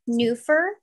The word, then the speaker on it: Neuffer: Hi, my name is Cheyenne Neufer, N-E-U-F-F-E-R.